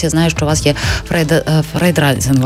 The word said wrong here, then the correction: фандрайзинг: але я мені важко вимовити. – Так, фандрайзингова компанія.